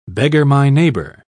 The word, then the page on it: beggar-my-neighbour: British English